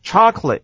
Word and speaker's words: chocolate: chocolate 美式念法，重音在第一个音节，那个 O 要念作 R 的声音，念作 ch。